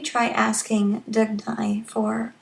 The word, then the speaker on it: Dugnai: I thought I would introduce the goddess Dagny. It's spelled D-U-G-N-A-I.